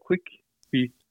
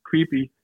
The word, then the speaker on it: Quibi: Du er udviklingschef og ekspert i tv-serier, og udtalte jeg det her korrekt, Quibi? – Ja, altså det står på Quick Bite, så det er Quibi, Quibi. Så Quibi. Det er sådan, man siger det.